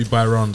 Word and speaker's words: Bahrain: How do you pronounce that one more time? – It should be Bahrain, sorry. – Wait, no, that's even wrong again. – Baran? – Bahrain. – Bahrain. Oh, Bahrain. Sorry.